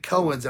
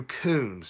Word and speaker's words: Koens: His last name is spelled K-O-E-N-S, and you would think Cohen's, and I know I do that every time, and Dad and Mom will get on me. So it's Andrew Coons.